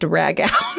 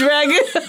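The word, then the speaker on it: ragù: And I ordered a dish with a side of corn ragu that I pronounced rag out. – Oh, no. Rag out. – And as soon as it came out of my mouth, I was like, that's definitely pronounced ragu.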